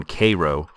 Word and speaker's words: Cairo: "That's how they pronounce the one in Egypt. The one in Little Egypt, they call that one Cairo.